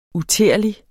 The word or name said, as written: uterlig